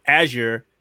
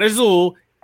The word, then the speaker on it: Azure: And he's thinking about using the technology of Azure, not Azul, Azure, I hate when people say that wrong, to get Xbox games out to those platforms.